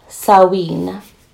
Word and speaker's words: Samhain: It's spelled S A M H A I N. So I'm glad that I read several articles because only one of them had the pronunciation.